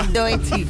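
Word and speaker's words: Doechii: Y a ver, ¿esto cómo se pronuncia? – Doechi. – Muy bien.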